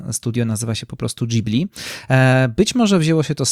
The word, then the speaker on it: Ghibli: Jedni mówią Ghibli, inni mówią Ghibli. W takim razie, jaka jest prawidłowa wymowa? Japończycy mówią Ghibli, to jest dość jednoznaczne,